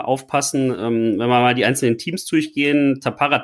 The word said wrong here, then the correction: Tampere: Tampere, südwestliches Finnland, 17-facher finnischer Meister, also da weiß man auch, was auf einen zukommt.